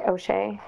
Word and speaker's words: O'Shea: O'Shea.